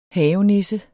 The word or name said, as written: havenisse